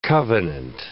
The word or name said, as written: covenant